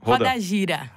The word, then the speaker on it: Roda Gira: One is called, and you have to correct me if I pronounce it wrong, Roda Gira, or Roda Gira. – Roda Gira.